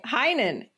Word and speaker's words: Heinen: Heinen.